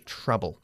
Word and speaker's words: trouble: Se escribe trouble, pero se pronuncia trouble, okay?